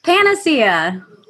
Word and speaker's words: Paniccia: You'll notice I didn't say your last name. – Because you don't know how to pronounce it, do you? – Forgot. That's right. So why don't you say it for us so we all know? – Panacea.